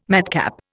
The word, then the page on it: madcap: Indian